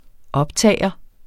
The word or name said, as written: optager